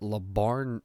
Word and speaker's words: LeBarn: And when I say that, I mean, I went on Twitter and I saw that LeBarn, I'm saying that like how it's spelled, by the way. L.E. Barn, B-A-R-N, James, was trending.